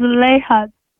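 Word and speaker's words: Zulaihat: Zuleha, I called yesterday. – You called yesterday? – Yes. – I'm not hearing the name properly. – It's Zuleha.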